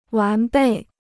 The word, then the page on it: 完备: wán bèi